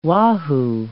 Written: wahoo

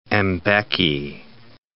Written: Mbeki

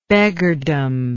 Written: beggardom

